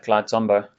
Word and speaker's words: Clyde Tombaugh: Clyde Tombaugh.